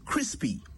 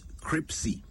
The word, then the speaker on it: crispy: Crispy, not cripsy. Try saying it slowly. Crispy. First, you say Chris. Iria nisinipi. Crispy. That is how you say it right.